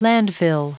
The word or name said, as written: landfill